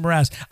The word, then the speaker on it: Mraz: I think I just said his name wrong. Jason Mraz. That's what it is. Jason Mraz.